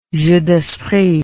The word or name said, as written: jeu d'esprit